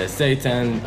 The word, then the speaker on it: seitan: Yeah, seitan. I mean, we call it, we don't like using that word because it sounds too much like Satan.